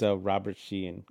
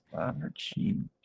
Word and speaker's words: Robert Sheehan: It's Robert Sheehan, because I have to say it right. I couldn't go without looking it up. I forgot his name, which is crazy, because Robert Sheehan. – Robert Sheehan.